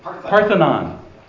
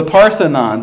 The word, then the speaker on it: Parthenon: Parthenon. – I don't know why I messed that up. Every time in my brain housing group, it goes the wrong way. The Parthenon.